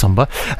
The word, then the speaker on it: SOMBR: So you don't know whether or not do you pronounce it S-O-M-B-R or do you do it like it looks and say Somba. I think it's Somba.